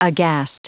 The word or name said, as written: aghast